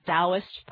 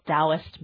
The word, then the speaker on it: Taoist: So Taoist is spelled with a T. – Right. – T-A-O-I-S-T, everybody. So just so you know what Jaya is talking about, it's the T is pronounced like a D.